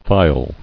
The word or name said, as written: Faille